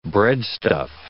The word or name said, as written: breadstuff